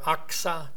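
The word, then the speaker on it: Achsah: Aksa, anybody named their daughter Aksa? No. It's spelled A-C-H-S-A-A, not O-X, Aksa.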